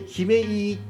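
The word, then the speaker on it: 姫飯: 姫いいっていうのは、えーと、お姫様の姫に、えー、飯って書いて姫いいって読むんですが、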